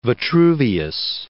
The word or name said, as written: Vitruvius